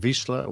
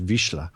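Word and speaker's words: Vizsla: It's a Hungarian name, so Wiesler. – Wiesler. – Yeah. But it depends on how you prefer it. The Hungarian pronunciation is Wiesler.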